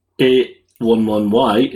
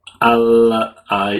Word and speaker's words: a11y: Ally is pronounced A Elven 11 Y, not ally.